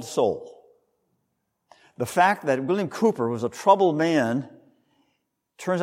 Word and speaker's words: Cowper: It looks like his name is Cowper, but it's actually pronounced Cooper.